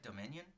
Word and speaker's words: Dominion: Jurassic World Dominion. – Oh, yeah, huh? Right? – That's how you say it, right? Dominion? – Yeah, you're saying it right.